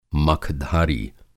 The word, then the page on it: मखधारी: Hindi